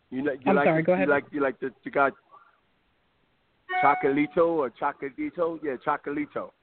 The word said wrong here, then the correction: Chocolatito: I'm saying the name right? Because I'm terrible with names. So it's Chocolatito. – Chocolatito.